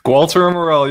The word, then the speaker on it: Gualter Amarelo: Gualter Amarelo, you got it, brother.